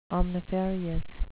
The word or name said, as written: omnifarious